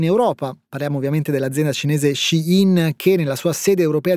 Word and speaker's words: Shein: e sappiamo esattamente come fa Shein, che si pronuncia così perché nonostante l'azienda sia cinese il suo nome è una crasi di due parole inglesi che vogliono dire lei dentro.